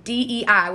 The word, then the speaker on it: dei: And so, taken from the Greek, it's DEI, which is pronounced as day, which means you should.